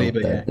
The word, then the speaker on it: Saliba: Is it Saliba as well? – Saliba, yeah.